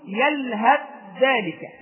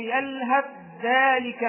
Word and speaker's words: يلهث ذلك: يعني إما أن أقول يلهث ذلك، لا أنطق تاء مطلقا، لا أنطق تاء، وإنما أدغم التاء بالذال إدغاما كاملا، فأقول أو تتركه يلهث ذلك مثل القوم، لا أجد تاء مطلقة، كأن التاء غير موجودة، تأتي بعد الهاء ذال مباشرة، فأقول يلهث ذلك، وهذا حال الوصف، ولا إشكال أن تصل الآية، فتقول أو تتركه يلهث ذلك مثل القوم الذين كذبوا بآياتنا.